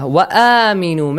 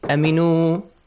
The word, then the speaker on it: وَآمِنُوا: وَآمِنُوا. Make sure it has a long sound. Let's talk about the tajweed rules here, okay? وَآمِنُوا. Both long sounds, okay? Long vowel sounds. آمِنُوا. وَآمِنُوا. Do not say أَمِنُوا.